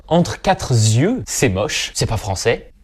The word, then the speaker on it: entre quatre yeux: « Entre quatre yeux », on le prononce avec un S, mais ce S, on l'écrit pas.